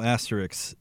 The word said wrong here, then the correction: asterisk: is, did I say that right? – Asterisk. – Isk.